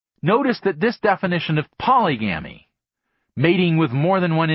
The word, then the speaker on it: polygamy: The third mating system is polygamy, sometimes pronounced polygamy, from the Greek polis, meaning many, it's where we get the word politics, and again gamos, meaning marriage.